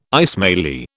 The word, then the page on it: Ismaili: British English